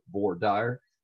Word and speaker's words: voir dire: And that is called the voir dire. Voir dire is the correct pronunciation.